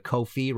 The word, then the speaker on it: Ko-fi: If you did like the show and want to support future episodes, please go to Ko-fi, rhymes with no fee, .com.